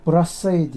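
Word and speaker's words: Brassaï: Telaffuzunu bilmiyorum nasıl okunuyor. Brassai diye okunuyor.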